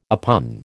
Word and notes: oppugn: American English

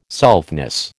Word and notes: selfness: American English

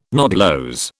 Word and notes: nodulose: British English

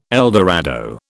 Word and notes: eldorado: British English

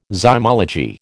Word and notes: zymology: American English

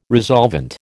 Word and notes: resolvent: American English

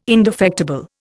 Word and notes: indefectible: Indian

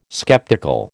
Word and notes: sceptical: American English

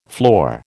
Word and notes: floor: American English

